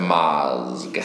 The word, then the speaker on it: Mazg: So there's a cultural group in your book and it's spelled M-A-Z-G. So first of all, tell me how to pronounce it. – I say it Mazg, and, okay. And in my mind, the longer you can draw out the Z and the more then it can kind of swallow the G, the better.